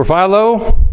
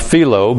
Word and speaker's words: Philo: For Philo, and by the way, some have suggested it was actually pronounced Philo, but most call him Philo, so we'll go with the latter.